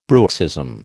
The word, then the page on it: bruxism: American English